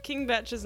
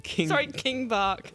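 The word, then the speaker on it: King Bach: I will say, for the first, like, however long I was participating and engaging with Vine, I thought King Batch's name was pronounced Kim Bark. King Bark. – King Bark.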